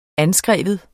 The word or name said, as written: anskreven